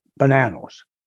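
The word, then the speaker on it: Bonanno: the Bonannos. Bonannos. Did I say that correctly? – The Bonannos, yes. – Yeah. – You did.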